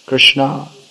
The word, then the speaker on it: Krishna: Even quite a common girl's name in Gujarat is Krishna, female form, Krishna, the long A at the end, Krishna.